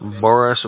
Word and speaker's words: Boris: Boris Badenoff. – Spell that for me. – B-O-R-I-S. B-A-D-E-N-O-F. – It's B-A-D-E? – Right.